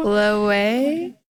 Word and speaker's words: Loewe: Wow, you know how to pronounce it now. – I love saying it now, Loewe. – You know how to pronounce it. Remember when I said it for the first time and you were like, what? – You taught me it's Loewe.